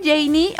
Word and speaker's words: Jenny: Por cierto, soy Janie. Oye, ya nos sigues en redes sociales, arroba Radio Disney MX, arroba Janie Capistrán. Mi nombre se escribe así, JENNY Capistrán, arroba Janie Capistrán.